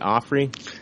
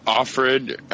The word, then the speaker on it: Offred: Is it Offrey? – Offred, I think is how you say it.